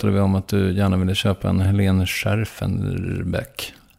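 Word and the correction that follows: Schjerfbeck: Jag kan inte uttala det. – Scherfbeck. – Ja. – Helene Scherfbeck. – Scherfbeck.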